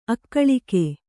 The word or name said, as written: ಅಗಲಿಕೆ